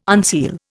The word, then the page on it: unseal: Indian